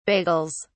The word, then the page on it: bagels: British English